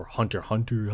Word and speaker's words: Hunter x Hunter: Like, I just started watching Hunter x Hunter because, like, or Hunter Hunter, how it's actually pronounced.